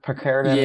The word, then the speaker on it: Picaridin: That's interesting what you just said there about the, is it Picaridin? Is that how you pronounce it? – Yeah, the Sawyer Picaridin.